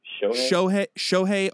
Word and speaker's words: Shohei: Shohei Ohtani.